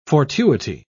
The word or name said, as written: fortuity